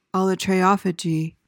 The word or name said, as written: allotriophagy